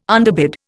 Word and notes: underbid: Indian

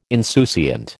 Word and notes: insouciant: American English

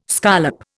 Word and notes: scollop: Indian

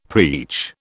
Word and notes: preach: British English

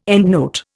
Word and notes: endnote: Indian

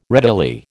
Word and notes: readily: American English

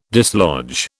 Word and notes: dislodge: British English

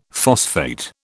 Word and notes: phosphate: British English

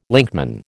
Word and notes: linkman: American English

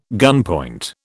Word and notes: gunpoint: British English

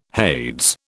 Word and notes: Hades: British English

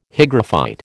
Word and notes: hygrophyte: American English

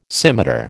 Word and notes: scimitar: American English

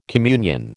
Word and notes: communion: American English

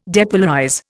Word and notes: depolarize: Indian

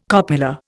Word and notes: copula: Indian

